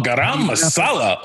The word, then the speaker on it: garam masala: Is it garam masala? – Oh, garam masala.